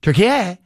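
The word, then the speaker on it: Türkiye: which now is pronounced Turkey. But it used to be just Turkey.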